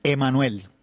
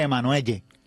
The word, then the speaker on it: Emanuelle: Emanuel. Parece que se escribe Emanuelle. – Okay. – Estilo como Michelle y eso, se escribe así, pero mi nombre es Emanuel.